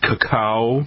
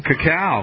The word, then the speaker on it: cacao: It's in the, how do you pronounce it? Cacao. – Cacao.